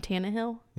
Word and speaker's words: Tannehill: and they also had a name that I didn't know a whole lot about, but I've heard, and that's Ryan Tannehill.